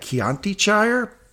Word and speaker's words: Chiantishire: I'm calling it Chiantishire because of the wine Chianti, and it's Italy, and they drank the entire episode.